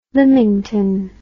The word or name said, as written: lymington